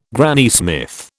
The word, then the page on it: Granny Smith: British English